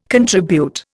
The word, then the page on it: contribute: Indian